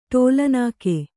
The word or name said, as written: ಟೋಲನಾಕೆ